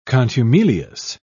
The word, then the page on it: contumelious: British English